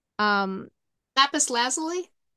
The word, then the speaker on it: lapis lazuli: This is blue, the Louisie, the blue Louisie. I don't know how to pronounce that. – Lapis lazuli.